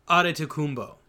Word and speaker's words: Adetokounmpo: Adetokounmpo.